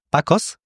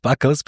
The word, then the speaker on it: Bakos: Ich glaube, ihr habt wahrscheinlich beide recht, denn tatsächlich ist der Name ungarisch und man spricht ihn eigentlich original schon Bakos aus, aber wenn man ihn original ausspricht, dann spricht man ihn ganz anders aus und deshalb halte ich es dann immer österreichisch und spreche es einfach aus, wie es steht und das ist halt Bakos.